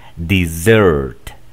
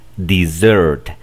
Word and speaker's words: dessert: Se pronuncia de la siguiente manera: dessert, dessert. El acento va en la segunda sílaba.